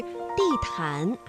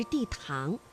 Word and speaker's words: 地毯: 那么在平时朗读的过程当中啊，你的发音是地毯还是地堂？这个安呢是前鼻韵母，气流呢是从鼻子前方出来的。